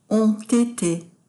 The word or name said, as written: ont ete